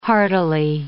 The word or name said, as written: heartily